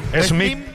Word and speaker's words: Smith: ¿Jorge, el SIP? – Smith. – ¿Cómo se pronuncia? Es TIP, ¿verdad? T-I-B. – No, M, Smith.